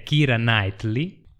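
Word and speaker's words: Keira Knightley: Ki- è Kira Knightley.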